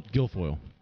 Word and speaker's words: Guilfoyle: Is it Guilfoyle? – Guilfoyle.